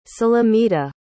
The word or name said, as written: sulamita